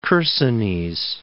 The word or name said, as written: Chersonese